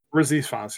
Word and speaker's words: Rhys Ifans: Rhys Ifans, yeah.